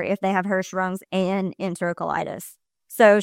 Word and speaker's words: enterocolitis: And she said, I recommend the ileostomy because then there are less complications and it prevents them from getting enterocolitis. Am I saying that right? – Oh, yeah. Yeah, that's the thing.